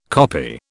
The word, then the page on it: koppie: British English